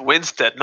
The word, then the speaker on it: Winstead: Is it Wanstead? Oh god, wait, I think I got her name wrong. Wait, cut this bit out. – I don't know. Was it Helena? – Yeah. And Mary Elizabeth Winstead, not Wanstead.